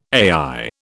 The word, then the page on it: AI: British English